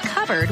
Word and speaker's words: cupboard: When we come back, why it's pronounced covered when it's spelled cupboard? What's up with that?